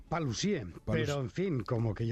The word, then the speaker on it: Paluzie: Con acento en la E. – Correcto. – Paluzie.